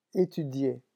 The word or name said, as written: etudier